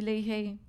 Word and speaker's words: Leahy: My social media is my name, Georgie Leahy, G-E-O-R. – Leahy. – G-I-E. – Is that how you say it? – L-E-A-H-Y.